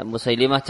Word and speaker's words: Musailimah: Ya, Musailimah cara membacanya adalah dengan bentuk tasghir. Sehingga Musailimah, karena dia tasghir. Bukan mukabbar namun musaghar. Bahkan tentang penamaan Musailimah ini ada ungkapan salah satu ulama namanya Tilmisani, beliau mengatakan man fatahal lam fawa aqdabu minhu. Siapa yang membacanya Musailamah, maka dia lebih bohong daripada Musailimah.